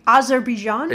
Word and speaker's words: Azerbaijan: Azerbaijan.